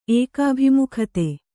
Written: ಏಕಾಭಿಮುಖತೆ

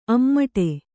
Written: ಅಮಟಿ

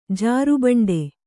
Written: ಜಾರುಬಂಡಿ

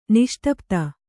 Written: ನಿಷ್ಟಪ್ತ

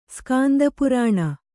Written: ಸ್ಕಾಂದಪುರಾಣ

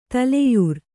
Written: ತಲೆಯರಿ

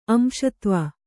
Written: ಅಂಶತ್ವ

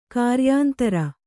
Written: ಕಾರ್ಯಾಂತರ

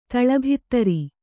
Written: ತಳಭಿತ್ತರಿ

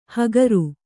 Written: ಹಕಾರ

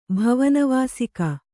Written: ಭವನವಾಸಿಕ

